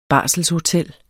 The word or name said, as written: barselshotel